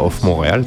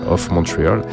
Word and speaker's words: of Montreal: Ah, Off Montréal, sinon. – Mais... Off c'est l'accent, francophone. Euh, mais comme c'est un groupe américain, il faut prononcer ça euh, Off Montreal,